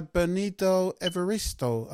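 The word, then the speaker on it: Bernardine Evaristo: And it was, I'm going to pronounce this, it was written by Bernardo Evaristo. Have I pronounced the name right, Lady Essie? – Yes, you have.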